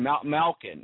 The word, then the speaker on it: Malkin: Speaking of really good guys, and Malkin, did I say that right? – Yeah, you got it.